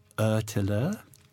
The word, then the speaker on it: ÖTILLÖ: And what was really great about that also is just how forthcoming all of them were about their experience competing in ÖTILLÖ. – Yeah, perfect pronunciation.